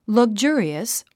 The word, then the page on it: luxurious: lʌɡʒú ə riəs; ラグジュリエス